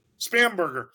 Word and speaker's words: Spanberger: And you had legislator, the Spanbaker, am I saying that right? What's her name? – Spanberger.